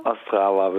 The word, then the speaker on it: Ostrava: Und zwar in Ostrava? – Ostrava, würde ich sagen, ja. – Erstmal nochmal ordentlich auch guten Morgen, Herr Sander. – Guten Morgen. – Sie persönlich, Sie waren eben in Ostrava.